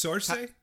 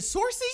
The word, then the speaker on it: Saoirse: I think it's Saoirse. – Is it Saoirse? – Saoirse Ronan? I think so, yeah. That's what I'd go with.